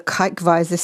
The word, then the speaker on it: Kijkwijzer: It's called the Kijkwijzer system. I'm reliably informed that's how it is pronounced.